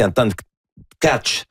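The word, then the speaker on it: Anton Tkáč: Et puis là, ben, il faut pas le prononcer Anton Tack. On avait tout fond en 76. – C'est ça. Selon ce que Juraj Benko, là, un auditeur d'origine slovaque, m'a dit, c'est Anton Catch.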